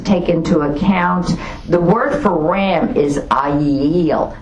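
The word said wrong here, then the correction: ayil (אַיִל): I'm saying that wrong, completely wrong. It's A apostrophe Y-I-L, but it's pronounced ayil, ayil. Okay, I think that's a little better.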